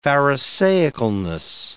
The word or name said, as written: pharisaicalness